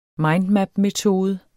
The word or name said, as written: mindmapmetode